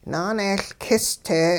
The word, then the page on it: naaneeyLkis-te: hupa